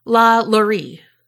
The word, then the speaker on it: LaLaurie: However, I learned that I was a peasant And Carrie told me the correct pronunciation, and it is La Lurie.